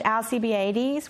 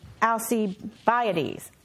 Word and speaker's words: Alcibiades: There is a, I always said Alcibiades, which is completely wrong. This is Alcibiades. That is how you say it, but my kids don't even know that to this day, probably.